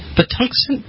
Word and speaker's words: Patuxent: And a senior advisor to the executive director of the Patuxent Partnership.